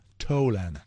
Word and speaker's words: Tolan: En 1908, un día como hoy, nació Eddie Tolan, o Tolan, no sé cómo se pronuncia, Teudi. Ninguna de las dos, como dije, ni Tolan, ni Tolan. Tolan.